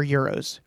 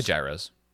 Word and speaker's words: gyros: No, do you say gyros or euros or euros? – I say gyros.